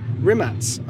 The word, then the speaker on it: Rimac: Okay, so welcome to the Top Gear magazine podcast, Mr. Bugatti Rimac. I'm finally pronouncing your surname correctly. – Yes. – I think. – I don't care how you pronounce it, it's fine either way, but you've done it very well.